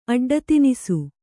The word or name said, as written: ಅಡ್ಡತಿನಿಸು